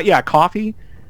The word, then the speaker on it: Ko-fi: It's Ko-Fi. It's Ko-Fi. Coffee. Yeah.